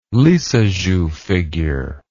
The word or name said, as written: Lissajous figure